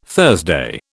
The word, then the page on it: Thursday: British English